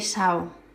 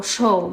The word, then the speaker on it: Shaw: que es sobre el empresario Enrique Sao, o Shaw, me parece que se pronuncia,